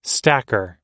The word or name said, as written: stacker